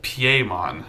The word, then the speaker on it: Piemon: In Japanese, it's spelled similar without the D, but it's pronounced like Piemon, and I think that's supposed to be like Pierrot, like the traumatic archetype, kind of.